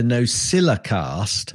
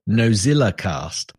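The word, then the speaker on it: NosillaCast: And if you're listening, Alison, you'll hear that I said that correctly because so many people go Nocilla cast, a little bit like Streisand. Well, it hasn't got a Z in it, folks, and that's what she's complaining about.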